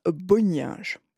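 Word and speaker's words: Bonynge: sous la direction de Richard Bonin. C'est le fameux de 1962 dont... Chacun a sa prononciation, en fait. Moi, j'aime bien dire Bonninge.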